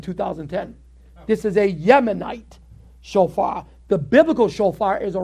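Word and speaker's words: shofar (שופר): Shofar.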